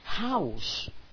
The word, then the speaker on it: House: House, casa. Acordaros de que la J, de que la H sí se pronuncia, y se pronuncia como una J.